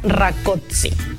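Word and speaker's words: Rákóczi: Estuve buscando la pronunciación correcta y al parecer se pronuncia rakotsi.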